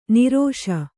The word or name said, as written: ನಿರೋಷ